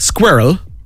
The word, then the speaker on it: squirrel: Squirrel. Funnily enough. – Squirrel. – Two syllables in a standard pronunciation, but people say it in one sort of squirrel.